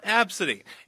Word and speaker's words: Abcde: Tracy's 5-year-old daughter has a unique name spelled ABCDE, but it's pronounced Absidy.